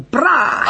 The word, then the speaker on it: braai: We put nothing on the lamb, straight onto the braai. Did I say it right? – 100%. Beautiful. The braai.